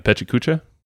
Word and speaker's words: Pecha Kucha: He's like, you know, you compress it to like 15 minutes, or even the I'm going to mispronounce it again, the Pecha Kucha. Did I nail it? – Oh, you said it right.